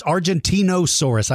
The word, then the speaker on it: Argentinosaurus: It's Argentinosaurus. I always say Argentinosaurus, Argentinosaurus.